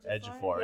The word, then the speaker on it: Ejiofor: Chiwetel Ejiofor, isn't it? – Ejiofor, yeah.